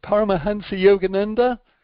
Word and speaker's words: Paramahansa Yogananda: If you're with a wise person, like he is a disciple of I can never remember how to pronounce the guy's name, Paramahansa Yogananda. – Oh, that's pretty good.